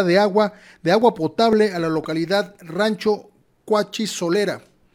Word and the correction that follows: Cuachisolotera: Cuachisolotera, si sí, lo pronuncié bien, Cuachisolotera, desde ahora,